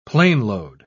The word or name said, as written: planeload